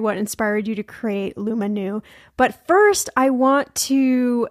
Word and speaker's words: Lumanu: Lumanu, right? Lumanu. – How am I? Lumanu.